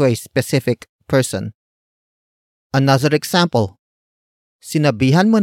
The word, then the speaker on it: sinabihan: Word for word it's sinabihan mo na ba siya.